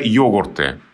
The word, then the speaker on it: йогурт: И вот это вот уже йогурты с ударением на «о».